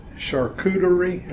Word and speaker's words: charcuterie: How do you say this? Charcuterie? Is that right? – Yeah.